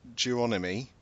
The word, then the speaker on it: Geronimi: So, you've got Clyde Geronimi... I think that's how you pronounce it, not Geronimo, and Manuel Gonzalez, both being inducted for animation.